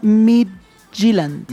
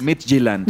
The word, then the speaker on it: Midtjylland: Pues yo no hablo noruego, pero yo diría Midtjylland. – Midtjylland.